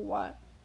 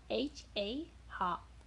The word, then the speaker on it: は: The particle wa, wa is pronounced wa, wa, not ha ha.